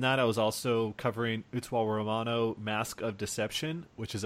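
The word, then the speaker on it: Utawarerumono: Utsuwa Romano. – Utsuwa Romano. – I'm not going to pronounce it the Japanese way because I can't pronounce my R's.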